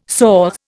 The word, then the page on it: soh: Indian